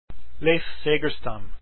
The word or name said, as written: segerstam